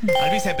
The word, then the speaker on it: Alvise: Al Baize. – Eso es correcto. Bastante mal pronunciado, pero es correcto. – Al Pérez. – Alvise Pérez.